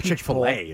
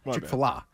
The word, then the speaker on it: Chick-fil-A: It's not Chick-fil-A. It's mispronounced. Chick-fil-A.